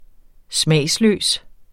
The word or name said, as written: smagsløs